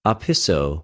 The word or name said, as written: ὀπισω